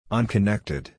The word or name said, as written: unconnected